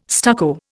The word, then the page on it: stucco: Indian